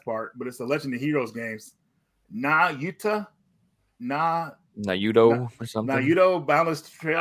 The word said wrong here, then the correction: Nayuta: Nayuta, yeah.